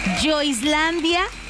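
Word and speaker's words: Joislandia: Así que entra a nuestra página de Facebook, Yo Islandia. Así como se escucha J-O-I-S-Landia, así se escribe.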